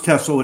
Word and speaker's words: Tessell: Tell me a little bit about what is, and am I pronouncing it right? Is it Tessell? Does it stand for anything? – Yeah, it's pronounced Tessell. It's short for tessellation.